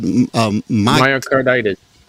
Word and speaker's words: myocarditis: Myocarditis.